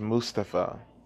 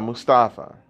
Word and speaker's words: Mustafa: It's actually pronounced Mustafa, not Mustafa.